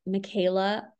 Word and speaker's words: Micaela: My username is at Micaela underscore D.